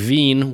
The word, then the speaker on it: Wien: You know, I had many German students who would pronounce the EI and IE's opposite, you know? Mm-hmm. So, so like, like Wien, which is German for Vienna, and then Wein, which is German for wine.